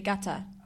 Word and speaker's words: Qatar: No, Gata. Like, as in the place that people pronounce Qatar, but it's actually Gata.